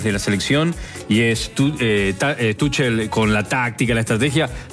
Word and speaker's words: Tuchel: Tuchel se pronuncia. – Ah, Tuchel. – En alemán. Es alemán.